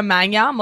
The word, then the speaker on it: Magnan: Yeah, so my name is Sabrina Magnan. Most people don't know how to pronounce my last name because it's a French last name.